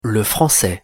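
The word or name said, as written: le français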